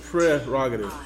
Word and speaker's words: prerogative: So look at this thing. P-R. So it's prerogative. – Really? – Yeah, pre. – Yeah. – It's my prerogative.